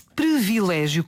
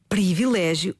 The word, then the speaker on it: privilégio: E a pergunta é, porquê é que nós pronunciamos esta palavra como se fosse privilégio, com um E ali a seguir ao R, quando é um I? – Interessante.